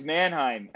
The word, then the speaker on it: Mannheim: But you did stay over there after, even with Monningham. There you go, Brooks. I got the name right. Yeah. you had the lockout there, but you did. – In where? – It's Mannheim. – Oh, Jesus. Oh, I almost got it.